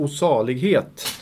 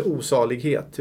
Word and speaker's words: O salighet: Jag vill sjunga en psalm som har nummer 234 och heter O salighet. – Ja, oj Sam, här sparkar jag omkull en lampa nästan. Och den heter inte osalighet, utan den heter osalighet. – Ja, lite med betoningen där.